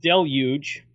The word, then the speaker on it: deluge: Not a Tom deluge, of course, but it's it's been raining fairly well. And that'll be it for my bad jokes for the night. So it's pronounced deluge, so there's that.